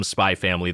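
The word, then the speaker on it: Spy × Family: Love me some Spy Family. There is an X in there, but as people have told me, you do not pronounce the X.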